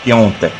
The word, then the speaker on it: Piątek: ¿me puedes confirmar que aunque se escriba Piątek se pronuncia? – Krzysztof Piątek.